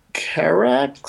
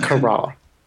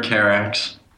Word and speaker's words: Carax: a 1991 film directed by Leo Carax. Is that how you pronounce it? Or is it Carra? – I've heard Carax.